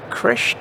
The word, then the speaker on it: Krish: Krish, K-R-I-S-H, Ramakrishnan, R-A-M-A-K-R-I-S-H-N-A-N.